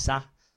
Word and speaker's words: sa: And apparently, the number one is pronounced as eel in Korean, and four is sa, so Blasser.